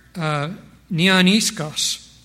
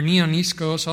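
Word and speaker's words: neaniskos: That's the Greek word neoniskos. Or neoniskos, I'll spell it N-E-A-N-I-S-K-O-S. N-E-A-N-I-S-K-O-S.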